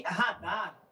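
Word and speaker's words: Rahdah: É, o nome radar, é, ele se escreve R-A-H-D-A-H. E é um nome hebraico, né?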